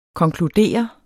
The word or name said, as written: konkludere